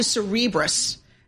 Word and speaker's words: Cerebras: How do you say your name? Like Cerebral. – Yes. – So Cerebras shares soaring.